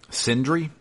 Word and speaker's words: Sindri: So, um, yeah, uh, we actually run into um, Brok's brother um, starts with the S, but I can't pronounce it. – Sindri. – Sindri is that it?